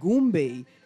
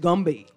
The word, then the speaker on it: Goombay: But it had been, and I can use this word, so gentrified that they no longer pronounce the word Goombay, they pronounce it Gumbay.